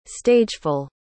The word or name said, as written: stageful